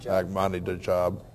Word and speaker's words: Ahmadinejad: Well, I think it's one of the reasons that you hear a lot of rhetoric from Hezbollah, from Ahmadinejad. I never can pronounce his name exactly Ahmadinejad.